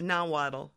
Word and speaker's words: Nahuatl: Is it Nahuatl language? – Nahuatl.